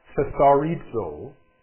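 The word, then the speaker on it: thesaurizo (θησαυρίζω): Comes from what Strong's numbers 2343, and it's pronounced something like thesaurizo, and it means to gather, to store, to accumulate, to hold in reserve.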